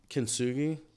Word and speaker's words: kintsugi: So John gave me kintsugi. Am I saying it right? – Yes, definitely.